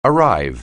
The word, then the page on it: arrive: anglais